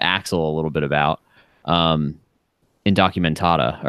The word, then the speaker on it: Indocumentada: It's Indocumentada,